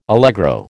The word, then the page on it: allegro: American English